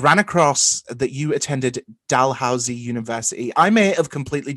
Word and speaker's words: Dalhousie: So first of all, you crushed it. It is called Dalhousie University.